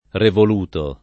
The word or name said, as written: revoluto